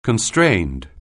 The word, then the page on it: constrained: anglais